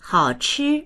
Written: hao3chi1

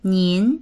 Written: nin2

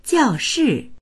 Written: jiao4shi4

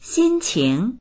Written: xin1qing2

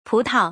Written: pu2tao

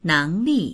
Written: neng2li4

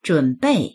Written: zhun3bei4

